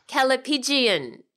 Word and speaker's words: callipygian: Kalibgian. No, I've said that wrong. – You've said it wrong. – Callipygian. C-A-L-L-I-P-Y-G-I-A-N.